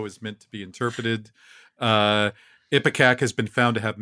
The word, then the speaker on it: Ipecac: Ipecac.